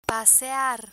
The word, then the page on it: pasear: Spanish